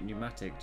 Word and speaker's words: pneumatic: When you say pneumatic, do you pronounce the p? – Pneumatic. No, but obvious, isn't it? – It's obviously.